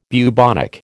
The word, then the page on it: bubonic: American English